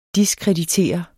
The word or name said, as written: DISKREDITERER